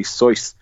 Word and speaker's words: Seuss: I read the other day that Dr. Seuss was actually initially Soyce, but it rhymed better as Seuss, and people kept mispronouncing it anyway, so he just fucking changed it.